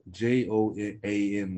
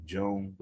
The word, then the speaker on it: Joan: Uh, it's spelled J O A N like Joan, but he's Spanish, so I guess it's Joan.